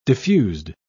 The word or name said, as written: diffused